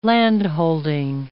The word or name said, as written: landholding